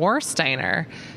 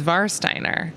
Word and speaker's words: Warsteiner: I've been saying Warsteiner, but it's Varsteiner. So just as a tip, right, for the weekend, all the W's are V's.